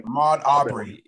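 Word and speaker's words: Ahmaud Arbery: I just watched the story on Ahmad how you say his name? I'm bad. – Ahmad Arbery. – Arbery, okay. – Arbery, yeah.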